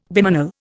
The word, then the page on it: bimanal: Indian